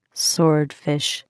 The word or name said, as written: swordfish